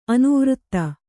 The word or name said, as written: ಅನುವೃತ್ತ